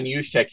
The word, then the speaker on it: Juszczyk: Also, and I always butcher this dude's last name, Kyle Juszczyk. – Juszczyk. – Thank you.